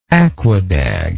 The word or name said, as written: aquadag